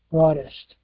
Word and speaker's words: wroughtest: And how would you pronounce this word here? Let me check this out if I can get a pronunciation. – Wroughtest.